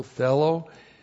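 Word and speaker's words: thelo (θέλω): looks like that in the Greek, T-H-E-L, long O, thelo, and thelo means to wish, to will, to want, to desire.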